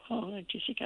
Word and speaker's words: acetic: I won't ask you to spell it, but say it slowly. – ACE. – Yes. – TIC. – Mm-hmm. – Acid. – Oh, acetic acid.